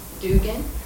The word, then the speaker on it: Dugan: K-I-N-L-I-N-L-I. Yes. – Okay. – I'm Ann, with an E. Dugan, D-U-G-A-N.